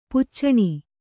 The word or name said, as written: ಪಿಚ್ಚನೆ